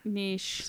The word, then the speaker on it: niche: Niche. – Some people will appreciate it. – Rhymes with quiche.